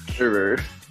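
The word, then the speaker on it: hooroo: Okay, so Molly says, oh no, oh well, roo-hoo. And then next week... – Hoo-roo. – Next week... – Not roo-hoo. – It says hoo-roo here. I just said it wrong.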